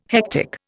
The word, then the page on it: hectic: Indian